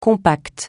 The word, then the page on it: compact: francais